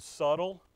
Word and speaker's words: subtle: Because it throws me off, and now I'm thinking, it's like that word subtle. I know the B is silent. But when I'm running through it in my mind, the B is going to come out.